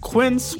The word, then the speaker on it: Quince: Today Explained's support today comes from Quince, which rhymes with since but is spelt with a Q-U.